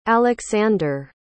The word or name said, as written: alexzander